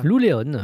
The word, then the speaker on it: Lou Léon: L-E-O-N, à l'espagnol, non? – Si tu le prononces Léon, oui. – Non, c'est lui qui m'a demandé de le prononcer comme ça. – Lou Léon. Oui, écoute. – Bon, voilà. – Léon en espagnol.